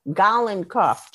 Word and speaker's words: Golinkoff: Golinkoff, G-O-L-I-N-K-O-F-F.